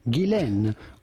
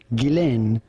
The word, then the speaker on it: Ghislaine: And in French, it can be pronounced either Ghislaine or Ghislaine. But in British, as is the case for her, her first name is generally pronounced Ghislaine Maxwell. – Ghislaine.